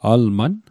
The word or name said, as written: alman